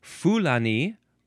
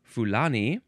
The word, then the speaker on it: Fulani: You can emphasize the first or the second syllable. Fulani or Fulani.